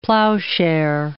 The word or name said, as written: plowshare